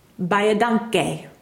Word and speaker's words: baie dankie: And the driver responded by saying, "You say thank you as baie dankie."